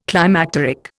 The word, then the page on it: climacteric: Indian